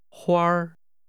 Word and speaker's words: 花儿: This is pronounced with a high level tone. – 再说一次。 – Flowers. – 花儿。